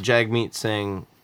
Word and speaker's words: Jagmeet Singh: I know that Jagmeet Singh one. – Yeah. – I don't know that that's how you pronounce his name. – It is.